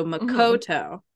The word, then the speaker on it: Makoto: The third syllable, the middle syllable is always emphasized. So Makoto, right?